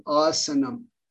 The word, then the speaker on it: asana: Now, I'm emphasizing the word here, asana, because I've heard some people pronounce it as asana. Okay, that's a very, very wrong pronunciation of this word. It's asana, not asana.